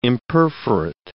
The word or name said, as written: imperforate